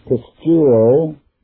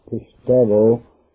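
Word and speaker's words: pistuo (πιστεύω): Now, the verb is pistuo, or if you favor the new pronunciation of Greek, it's pistevo. But being old-fashioned, I'll stick with pistuo,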